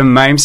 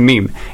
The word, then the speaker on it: mème: En fait, en français, je crois que même c'est la forme qui est la plus acceptée. Mais honnêtement, je dirais qu'il y a beaucoup de personnes qui utilisent la manière anglophone de prononcer le terme et qui disent meme.